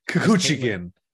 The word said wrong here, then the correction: Chookagian: Kuchukian. Oh, that's how you say it. Kuchukian.